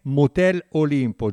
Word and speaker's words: Motel Olimpo: Andiamo a salutarci allora con questa canzone che dovrei pronunciare bene: Motel Olimpo, giusto, Niccolò? – Giustissimo.